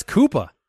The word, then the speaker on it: Koopa: Cooper Cup of Coffee. Look, Footclan. – Oh, that's Koopa. – Koopa. That's Koopa Cup of Coffee. – Like King Koopa Cup of Coffee? – Exactly right. Spelled the same.